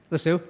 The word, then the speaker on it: Lisieux: Most of us probably know St. Teresa of Lisieux. I don't know how to pronounce it, Lisieux, but that's as close as I can get. Anybody know the right pronunciation? Lisieux, okay? Lisieux, okay, great.